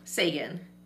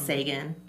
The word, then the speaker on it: Ce'gon: Yeah, it's Sagan. I've just gotten to the point now where it's mispronounced so much that if somebody says my name, I reply with the correct pronunciation.